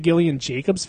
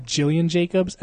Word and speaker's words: Gillian: I'm a big Gillian Jacobs fan, even though I used to call it Jillian Jacobs, and then someone was like, hard G, soft J.